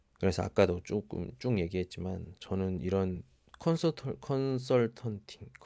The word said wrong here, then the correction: 컨설팅: 그 맞나요? 발음이 컨설턴팅 컨설팅 컨설팅 어, 컨설팅 예.